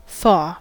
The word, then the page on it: thaw: American English